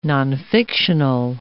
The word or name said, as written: nonfictional